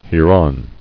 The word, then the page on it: Hereon: here·on